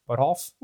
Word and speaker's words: Buttolph: We're talking with Ava Labs, and from them, we have Stefan Buttolph. How do you say your last name? Buttolph? – Yeah, so Stephen Buttolph. Yeah, it's like Rudolph, but with a butt in front of it.